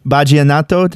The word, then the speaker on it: Vallenato: Vallenato, did I say that right? – Yeah, exactly.